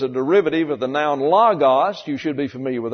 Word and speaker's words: logos: Logos, L-O-G-O-S. These are omicrons here, sound like a short A in the English, logos.